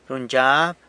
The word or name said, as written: runyzhyab